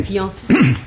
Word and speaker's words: fiancé: Prononciation et lecture, conversation et lecture, c'est fiancé, c'est-à-dire deux syllabes. Bon, il ne faut pas prononcer un i, mais on prononce un iode, fian, une seule syllabe, voyons.